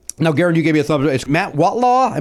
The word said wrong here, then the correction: Wardlaw: Am I pronouncing his name right? – Jeff Kyles and Matt Wardlaw. – Wardlaw. I apologize to Matt for not pronouncing his name correctly, but it was a good time, and I look forward to hopefully being on with those guys again and talking.